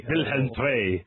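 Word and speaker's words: Willem II: Is that actually how you pronounce it, or are you just saying that to make it rhyme? – It's pronounced Wilhelm Twey. – Wilhelm Twey.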